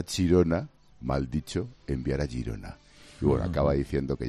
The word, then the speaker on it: Girona: Bueno, pues enviar a Chirona era la pronunciación cateta de enviar a Gerona, que era aquella cárcel. Girona, Chirona.